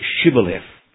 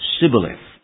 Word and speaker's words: Shibboleth: This was because their dialect couldn't pronounce the SH in the word Shibboleth. And instead they would say Sibboleth.